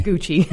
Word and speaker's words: Gucci: Rhymes with yucky. – Yeah, just no, tell me. – Gucci. – Yeah, Gucci. – Or yucky.